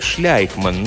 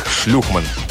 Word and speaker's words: Шлейхман: Уильям Шлейхман произносил свою фамилию как Шляйхман, но многие из тех несчастных, которым он делал ремонт, называли его не иначе, как Шлюхман.